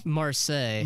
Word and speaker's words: Marseille: He brought me a beer opener from, is this pronounced Marseille? – Marseille.